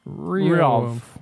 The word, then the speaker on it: Riohv: Riohv. Dave had a stroke when he was trying to say it. – Riohv. R-I-O-H-V.